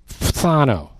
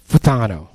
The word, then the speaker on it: phthano: It's spelled P-H-T-H-A-N-O. Phthano. Phthano. It sounds like your CD skipped.